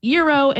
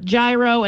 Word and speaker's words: gyro: And I hope SKS Communications does not get mad at me because I recently learned that it's pronounced Euro and not gyro, and I don't want anyone coming for my neck.